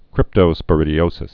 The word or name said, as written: cryptosporidioses